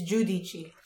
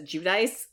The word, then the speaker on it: Giudice: Or G-I-U? Maybe Giudici. It's Giudici. – Okay, yes. I have heard it said that way, but everybody kind of says Giudice, but... – My God. – It's not hard to pronounce the D.